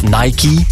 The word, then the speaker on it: Nike: En Nike, of Nike, ja, zo moet je dat eigenlijk uitspreken, wordt steeds minder populair, zo blijkt.